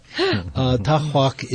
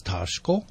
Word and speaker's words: Tockhwock: In our dialect, actually, it would be pronounced as Tashko, so I use an upstate version of it. I spell it T-O-C-K-H-W-O-C-K.